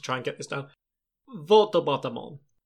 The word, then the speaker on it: Volto Bautamon: Yeah, Volto Bautamon is the correct pronunciation because Volto means mask, okay. And Bauta also means different kind of mask, so he's Mask-Mask-Mon.